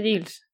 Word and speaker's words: Giles: Giles pero pronunciado Gilles, es que ya no me acuerdo. Sí, Gilles.